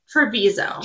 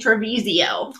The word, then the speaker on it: Trevizo: I was editing the video, and I was like, that's not how you say her name. – What is it? – I think it's Alexi Treviso. – Treviso. Okay. – I kept saying Trevisio.